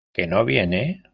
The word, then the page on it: viene: ispanskii